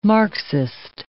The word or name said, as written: Marxist